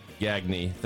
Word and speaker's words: Gagne: Ryan Gagne, thank you so much for joining me.